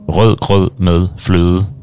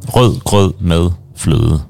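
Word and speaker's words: rødgrød med fløde: The test, usually if you want to test people's pronunciation in Danish, you have to say the name of a dessert called rød grød med fløde, because it's all these like Ø and D sounds.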